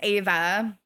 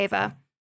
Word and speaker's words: Eva: And Eva, which I don't actually know if you pronounce her name Eva or Ava. – I think it's Ava. – Yeah. Okay, Ava.